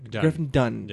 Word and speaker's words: Griffin Dunne: Is it Griffin Dunne? – Dunne. – Griffin Dunne. – Yeah. – It's another Cannes riddle. Was it Dune? Dunne? Yes, Griffin Dunne.